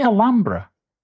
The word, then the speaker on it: Alhambra: Did you say Alhambra? – Yes, I'm used to pronouncing it that way.